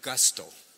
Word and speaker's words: gusto: Tú sabes que hay una palabra en inglés que es la misma palabra, gusto, pero pronuncian gasto.